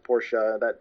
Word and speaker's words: Porsche: And in the commercials and everything, it's pronounced Porsche. That is the correct way to say it.